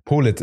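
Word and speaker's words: Politt: Polit ist wichtig, ne? – Polit, ganz Polit.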